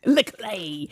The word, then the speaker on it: le-Clay: How do you say it? I'm, I'm Leclay. Leclay.